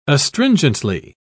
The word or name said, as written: astringently